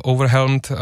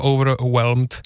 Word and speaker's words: overwhelmed: A pak jsem tu měl ještě jeden ohlas jednoho fanouška, takového trošku všetečného, který mě poopravil výslovnost, tak samozřejmě overwhelmed není úplně správně vysloveno, je to overwhelmed, až jsem z toho sám overwhelmed.